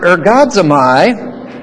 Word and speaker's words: ergazomai: And that word labor is the word ergazomai, In English, it's spelled this way, E-R-G-A-Z-O-M-A-I.